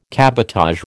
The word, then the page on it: cabotage: American English